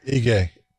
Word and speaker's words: Ige: Ige. – Ige.